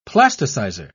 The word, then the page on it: plasticizer: British English